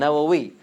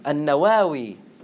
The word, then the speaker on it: Nawawi: Like they did, Al-Bukhari and Nawawi. So he's called Nawawi from Nawa, but there's another way to pronounce his name. Al-Nawawi. They're both accepted. Al-Nawawi and Nawawi, which is what is commonly known as.